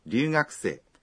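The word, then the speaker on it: Ryugakusei (留学生): Ryugakusei. – É estudante estrangeiro. – A pronúncia de ryu na palavra ryugakusei parece que é feita com um L, apesar de ser escrita com R.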